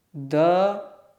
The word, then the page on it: d: sanskrit